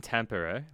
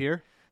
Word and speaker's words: Tampere: Yeah, so because he's in Sweden, because he's in Tampere, where you know the world championships are happening. – Tampere? – Tampere? No, no, it's not Tampere.